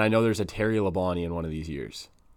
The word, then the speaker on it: Labonte: It's Bobby Labonte. – Yep. Is that how you pronounce that? – Yeah.